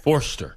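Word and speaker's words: Foerster: Forster. – So F-O-E-R. – Right. – S-T-E-R.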